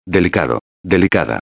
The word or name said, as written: delicado-a